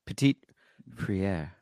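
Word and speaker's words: Petit-Frère: Petit Frere.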